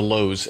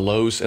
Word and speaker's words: Louhs: Friends, we're on Facebook, Twitter, Instagram, and YouTube, all under the headings of The Lowes. And that last name is quite unique. It's spelled L-O-U-H-S. It's The Lowes, and that's all of our platforms are under that heading.